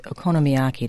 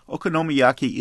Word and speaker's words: okonomiyaki: I want to begin with a review of a restaurant that serves a dish called okonomiyaki.